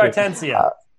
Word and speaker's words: Stuttgartensia: Stuttgartensia. – Yeah, that's the one. – he's asking for a comparison between the 1525 Blomberg, which was used in the King James, and the current Biblia Hebraica Stuttgartensia.